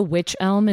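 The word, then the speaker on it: wych elm: And also, Witch Elm is spelled W-Y-C-H. So it sounds spooky and fun, but it's not like the normal witch.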